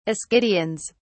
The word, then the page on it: ascidians: British English